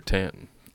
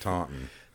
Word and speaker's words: Taunton: Is it Taunton or Tanton? – Taunton.